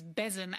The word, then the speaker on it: besom: You might actually pronounce it as the Scots do, as besom, and it means broom.